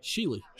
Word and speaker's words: Shealy: Shealy.